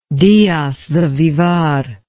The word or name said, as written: diaz de bivar